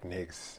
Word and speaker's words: Technics: Techniques. And that's for the people who are listening. It's spelled Tech, T-E-C-H-N-I-C-S, right? – Yeah, correct.